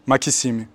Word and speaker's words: Maxime: Makissime, c'est comme ça que mes grands-parents prononcent mon prénom.